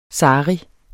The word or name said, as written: sari